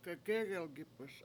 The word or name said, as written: gegEgelgIp1s